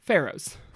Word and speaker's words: pharaohs: First off, you were pronouncing it wrong. It is pronounced pharaohs.